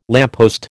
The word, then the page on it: lamppost: American English